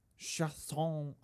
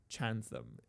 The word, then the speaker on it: Chatham: Yeah, maybe I'm saying Chatham wrong. Maybe it's like French. I mean, I don't know. The state, the province of Ontario is in French. Chasson. I don't know, man. So it's spelled Chatham. So I think it's just Chatham if you pronounce it in anglicized. If it's anglicized.